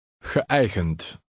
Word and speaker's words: geëigend: The two dots above the second E indicate that the first E and the EI are pronounced separately. Try to read 17. – Geëigend.